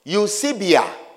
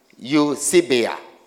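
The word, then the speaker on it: eusebeia: Godliness is translated from the Greek word eusebia.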